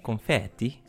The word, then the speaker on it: confetti: Uh, confetti, zo moet je het eigenlijk uitspreken.